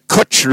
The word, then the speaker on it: cwtch: The Kutch Room, K-U-T-C-H. That's not how you spell it. That's how you see it. Kutch, it's a Welsh word. It's spelled C-W-T-C-H.